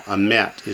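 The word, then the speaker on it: Amet (אמת): Normally with two sigol, you'd accent the first one. That's why they had to put the shava under it to make sure you accent the last syllable. Amet is truth.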